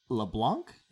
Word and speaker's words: LeBlanc: We've got Wade LeBlanc.